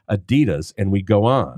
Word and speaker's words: Adidas: But everyone else in the world, everyone else in the world, pronounces it Adidas. Do you know why? – I don't know. – Because the guy who started the shoe company's name was Adidas. – Oh, that's how he pronounces it.